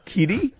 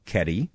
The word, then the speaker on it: Kedi: Kitty? – That's a word. – Well, no, it's spelled K-E-D-I. – Oh, Keddie, all right.